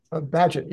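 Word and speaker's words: Bagehot: You're a financial historian, Jim, and you wrote a book recently about Walter, I think it's pronounced Behat. – Badgett, yeah.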